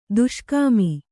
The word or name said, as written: ದುಷ್ಕಾಮಿ